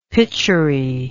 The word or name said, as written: pituri